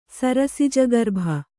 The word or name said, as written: ಸರಸಿಜಗರ್ಭ